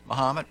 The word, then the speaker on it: Mahomet: And then tomorrow it's Mahomet, Illinois. Am I pronouncing it? – I think so, yeah. – Mahomet, as in Ali.